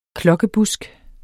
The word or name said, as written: klokkebusk